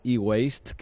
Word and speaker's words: eWaste: De hecho, mucha gente lo pronuncia diferente, lo pronuncia eWaste, sí. ¿No? Pero lo como has pronunciado tú es como sí. Es correctamente dicho.